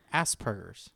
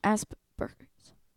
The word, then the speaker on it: Asperger's: Asperger's. – Asperger's.